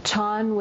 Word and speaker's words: Chan: Things we'll be talking about come largely from the chan, which is the Chinese pronunciation of the word that's pronounced zen in Japanese, the chan and zen and Taoist and Tibetan traditions, with some South Asian traditions out of which particularly the Tibetan ones came.